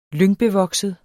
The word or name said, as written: lyngbevokset